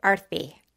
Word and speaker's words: Arthi: Almost perfect, like a T-H, Sanskrit T, Arthi.